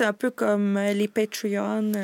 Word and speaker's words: Patreon: Ouais, Patreon.